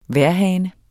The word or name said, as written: vejrhane